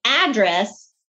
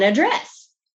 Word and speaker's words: address: So think about heteronyms, okay, and how the way we put different stress on different words changes the meaning. It's the difference between address and address. So it's a completely different meaning. One means a location and the other means writing down a location. We changed it from a noun to a verb just based on syllable stress.